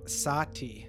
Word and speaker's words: Satie: Is his last name S-A-T-I-E? – Yes. But okay. – According to Wikipedia, it's pronounced Sati. I might be getting that wrong. – I have no idea. Yes, but I've seen that name. – S-A-T-I-E.